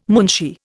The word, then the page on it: munshi: Indian